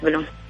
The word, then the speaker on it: بلوم: آه، بلوم صح؟ هذا النطق الصحيح روضة بلوم ممتاز أيوة روضة